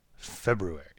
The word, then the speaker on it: February: So that's VaxFacts February. So that's what that is. – Cool. – And I pronounced the R in February right there on purpose, so people remember that that's in there in the hashtag.